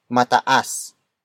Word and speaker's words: mataas: It means more, indicating that the word it introduces is the attribute being compared in this sentence, which is mataas. Mataas. Mataas. Mataas is spelled as M-A-T-A-A-S.